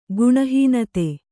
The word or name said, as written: ಗುಣಹೀನತೆ